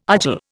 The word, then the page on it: argil: Indian